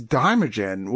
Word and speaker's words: Daimajin: And I was like, what the fuck is Daimajin? Well, Daimajin is like a big ancient stone god,